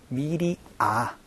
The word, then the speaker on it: media: 자, 여기에서 발음 팁을 하나 말씀드리면요, 미디어가 아니고 미디아 아로 발음된다라는 거 조금 주의를 해주시면 좋을 것 같아요.